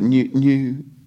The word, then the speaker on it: Neuquén: Neuquén. – It's like now and then a kin, is that right? – Neu.